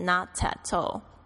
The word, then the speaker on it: Not at all: Alright, 然后再来呢还会有这个 Not at all, Not at all, 如果是英式发音的话可能这个 T 会变得很重，会变成 Not at all Not at all, 好，这个就是一点也不，一点也不，一点都不用写，就是不用写的意思。